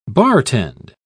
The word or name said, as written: bartend